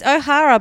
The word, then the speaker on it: O'Hara: O'Hara. Yeah, it's O'Hara, but maybe it's pronounced O'Hare. Catherine O'Hare. – No, I think it's O'Hara.